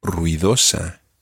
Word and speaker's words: ruidosa: And the word for noisy in Spanish is ruidosa. – Ruidosa. – Ruidosa. – Ruidosa. – Ruidosa. – Ruidosa. – Sí. There are four syllables in that word. Ru, I, do, sa.